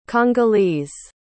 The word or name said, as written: congolese